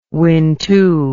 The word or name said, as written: wintu